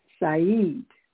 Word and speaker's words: Sayyid: A-S-W-A-D. Aswad. Black in Arabic is Aswad. The term Saura is a feminine form. How do you say master or mister in Arabic? Sayyid, from the root word for black. Literally, it means black. Sayyid. S-A-Y-Y-I-D. I say Sayyid. Sayyid. Y-Y-I-D.